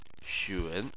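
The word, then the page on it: xun: tone 3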